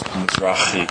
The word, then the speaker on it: Mizrachi: And the real pronunciation is Mizrachi and like an Israeli pronunciation.